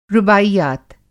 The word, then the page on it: रुबाईयात: رباعيات